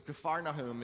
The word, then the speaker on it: Capernaum: Some people say Capernaum, some people say Capernaum. The actual, what it is in Hebrew is Capernaum. Okay, Kfar is village, Nahum is Nahum, okay, village of Nahum. So Capernaum is really how you say it. So if you're wondering, just say that, Capernaum.